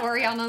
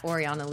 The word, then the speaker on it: Oriana: Twitter at Miss Oriana Leo. Instagram and everything else is at Oriana Leo. – So spell that. – O-R-I-A-N-A. L-E-O literally means golden lioness in Italian.